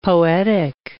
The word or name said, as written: poetic